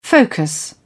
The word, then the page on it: focus: British English